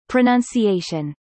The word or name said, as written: pronunciation